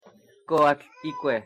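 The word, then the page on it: coatlicue: Nahuatl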